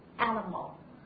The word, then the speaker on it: animal: Animal. 动物，对不对？啊，美式的发音呢，大家注意第二个音节，就是那个以 i 啊这个拼写的音节呢，它发的是一个弱化元音 animal，它不是 animal，是 animal。